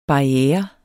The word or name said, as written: barriere